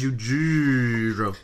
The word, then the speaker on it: Yujiiro: Y-U-J-I-I-R-O. – Was that Flash again? – Oh, it's Yujiro. – Yeah, the second I is silent.